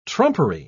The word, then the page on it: trumpery: British English